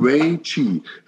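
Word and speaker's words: Wei Qi (危机): Das chinesische Wort für Krise ist Wei Qi. Das finde ich sehr spannend. Also Wei Qi. Das sind ja zwei Satz, zwei Silben.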